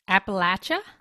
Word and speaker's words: Appalachia: So the correct pronunciation is what? – Appalachia.